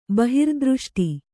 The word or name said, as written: ಬಹಿರ್ದೃಷ್ಟಿ